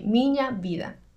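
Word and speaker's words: Minha Vida: El nombre se pronuncia como una ñ, Miña Vida.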